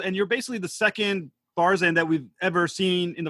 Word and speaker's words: Barzan: So on Discovery, you play I'm going to pronounce this probably wrong, Barzan? – Yeah, that's right.